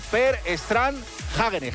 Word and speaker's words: Per Strand Hagenes: La ha ganado un noruego, Per Strand Hagenes, que esto se pronuncia así literal, sí, porque he llamado...